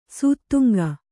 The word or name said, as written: ಶತಾಂಗ